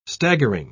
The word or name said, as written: staggering